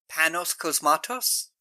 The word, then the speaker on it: Panos Cosmatos: Panos Cosmatos.